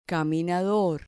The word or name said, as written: caminador